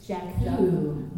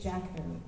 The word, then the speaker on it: Jakku: Jakku. – Not Tatooine. – It's Jakku. And it's spelled J-A-K-K-U.